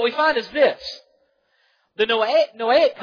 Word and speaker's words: Noahic: The first of these is the Noahic, N-O-A-H-I-C, if you want to know how to spell it, Noahic covenant.